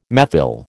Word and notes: methyl: American English

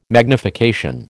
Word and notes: magnification: American English

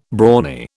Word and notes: brawny: British English

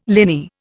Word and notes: liny: Indian

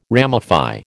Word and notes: ramify: American English

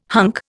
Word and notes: hunk: Indian

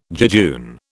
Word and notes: jejune: British English

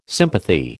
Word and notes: sympathy: American English

